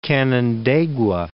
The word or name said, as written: Canandaigua